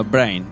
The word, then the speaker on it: Brain: Brian, no, uh, no, Brain, sorry.